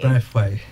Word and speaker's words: Braithwaite: Braithwaite.